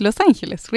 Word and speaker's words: Los Angeles: Aber eigentlich Los Angeles ist auf Spanisch und Leute denken, das ist etwas auf Englisch. Und es ist Los Angeles, richtig gesagt.